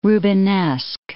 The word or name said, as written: Rubenesque